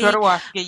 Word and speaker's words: Jodorowsky: Jodorowsky, yes.